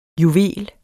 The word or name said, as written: JUVELEN